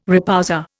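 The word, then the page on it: repoussé: Indian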